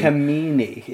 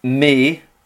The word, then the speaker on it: Alcmene: Alcmene. It's an emphasis on the me, hence why it's capitalised. Although, to Alcmene.